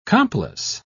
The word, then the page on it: complice: British English